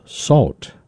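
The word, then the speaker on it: Söwt: Allen, who spent some time wandering about the Gnawa culture of North Africa, and he emerged with this album titled Salt. It's the best pronunciation I can give it. It's spelled S-O with an umlaut over it, W-T, and that's a word from the musical culture he studied.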